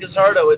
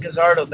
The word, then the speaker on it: Guzzardo: My formal name is Ryan Gazzardo. If you want to look me up on Facebook, look it up. Ryan Gazzardo. That is spelled G-U-Z-Z-A-R-D-O.